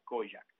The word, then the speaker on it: Kojak: Kojak se escribe K-O-J-A-K, Kojak.